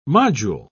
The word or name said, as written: module